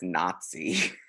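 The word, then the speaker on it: Nazty: Next we have Nasty Nuisance. – I'm pretty sure it's Nazi. That is how you spell it.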